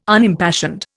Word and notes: Unimpassioned: Indian

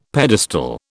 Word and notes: pedestal: British English

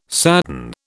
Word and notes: sadden: British English

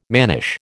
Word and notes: mannish: American English

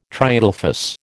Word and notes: triadelphous: American English

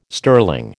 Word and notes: sterling: American English

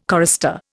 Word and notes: chorister: Indian

